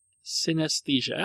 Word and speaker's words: synesthesia: Do you also have synesthesia? Synesthesia. I pronounced it correctly.